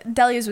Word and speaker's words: Delia's: Delia's? – Yeah. I think that's how you say it. – Is that it? – Yeah. Delia's was great. That was Delia's, actually. – Delia's. Thank you. That sounds right.